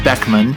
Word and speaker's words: Beeckman: So just forgive me, because in the podcast, I think I do refer to her as Teresa Beekman one time. But it is Teresa Beckman, spelled B-E-E-C-K-M-A-N. That's my out, is that it's sort of spelled like Beekman.